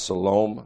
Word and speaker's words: Salome: John's mother was Salome, S-A-L-O-M-E. I've heard it pronounced Salome, Salome, however you want to pronounce it.